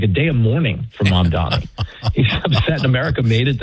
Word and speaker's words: Mamdani: Tufti, now how does, and by the way, it does seem that Mamdani is, I always say it wrong. Is it Mamdani? Mamdani. Yes. Mamdani.